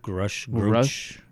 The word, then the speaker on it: Grusch: And it's like all very strange things to be saying, and it leads us to where we are today, where, you know, how do you pronounce his last name? David's last name, Grush? – Grush. – I keep calling him Grush.